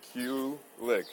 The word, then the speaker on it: Kulick: Kulik. K-U-L-I-C-K. – How do you pronounce that? – Kulik. So like the letter Q and then the word lick.